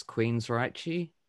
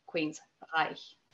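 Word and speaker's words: Queensrÿche: I mean, if you look at it from the way that it's spelt, though, like ignoring the umlaut on the Y, that C-H-E sound, for Germans anyway, it's Reich. So Queensreich.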